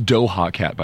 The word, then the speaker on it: Doja Cat: It's Doha Cat, by the way. You're saying it wrong.